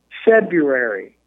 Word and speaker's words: February: I don't know what that means, but it is for this ceremony that we get the name of our second month, February. February. – February. – Why is February written like that? I don't pronounce it that way.